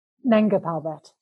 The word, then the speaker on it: Nanga Parbat: Is it Nanga Parbat? – Nanga Parbat.